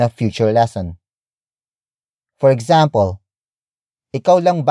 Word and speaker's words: ikaw: Ikaw lang ba ang kakain.